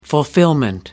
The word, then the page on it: fulfilment: American English